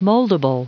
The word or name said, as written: moldable